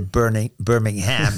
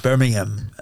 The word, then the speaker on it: Birmingham: You Americans say Birmingham for the same spelling. In England, we call it Birmingham, and that's where he had gone to live the last couple of years, near his daughter and son-in-law.